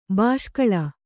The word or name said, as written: ಬೆಸಕೋಲು